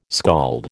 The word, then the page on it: skald: American English